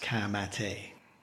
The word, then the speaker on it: Ka Mate: So we've got Te Rauparaha, 1905, Kapa o Pango, and finally, Ka Mate, which is spelt like a Ford Ka, and the second word is spelt like the English word mate, but it's pronounced Ka Mate.